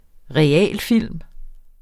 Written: realfilm

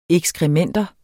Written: ekskrementer

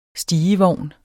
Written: stigevogn

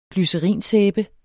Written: glycerinsæbe

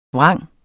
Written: vrang

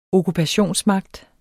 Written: okkupation

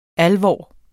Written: alvor

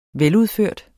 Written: veludført

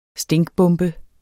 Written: stinkbombe

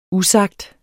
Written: usagt

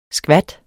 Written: skvat

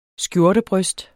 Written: skjortebryst

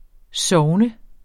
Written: sogn

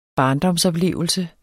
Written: barndomsoplevelser

